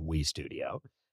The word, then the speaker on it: W3 Studio: It's written out as W3, but we pronounce it We Studio.